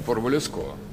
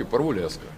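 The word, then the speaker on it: Parvulesco: По-французски, конечно, Парвулеско. Да. Но по-румынски Парвулеско.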